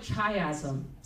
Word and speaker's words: chiasm: It looks like chiasm, C-H-I-A-S-M, chiasm, chiasm. Not chasm, because it has an I. C-H-I-A-S-M.